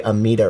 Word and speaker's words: Amedda: You know, Masamida, and I'm going to go the Amita route is kind of how I've always pronounced it.